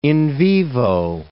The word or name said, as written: in vivo